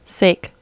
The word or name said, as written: sake